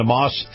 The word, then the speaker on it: Maus: Now, Moss is spelled M-A-U-S, and